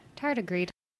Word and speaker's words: tardigrade: Tardigrade? – Tardigrade.